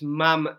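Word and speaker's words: ma'am: It's Mam as in ham, not Mom as in right.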